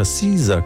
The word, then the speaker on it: SZA: SZA è SZA, però ho scoperto che si pronuncia SZA come la salad, senza R.